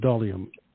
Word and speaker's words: dolium: The Roman dolia, D-O-L-I-A. Did you recall the pronunciation of D-O-L-I-U-M? – Yes. Oh, I-U-M. Dolium. It's Latin. So that's how, I don't know if that went through or not. Let me try it again. Sounds like volume, no.